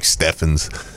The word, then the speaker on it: Stevens: His overseas stuff is like you look him up, and it's spelled Stevens, mm-hmm. but it's like Steffens.